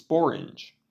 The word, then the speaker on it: sporange: An old word for sporangium is sporange, which happens to be the only English word to rhyme with orange.